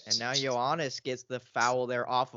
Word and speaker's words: Johannes: And I'm surprised, as I heard the broadcaster say it, so I guess I will repeat it. It is Johannes. I'm surprised that's the way they pronounce her last name.